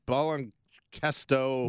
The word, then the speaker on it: Baloncesto: He appeared in 10 games, and then on January 7th, he signed with Garzas La Plata of the Liga Nacional de Baloncesto. – Nailed it. – Thank you. That's exactly how it's pronounced. – I believe it's Baloncesto.